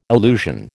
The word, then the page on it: illusion: American English